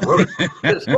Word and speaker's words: RotaTeq: In fact, the first time I did a story, I said there's a new vaccine called Rotate And he called me at six in the morning and said, you're pronouncing it wrong. Change the tape. It's Rotateq.